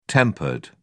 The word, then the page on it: tempered: British English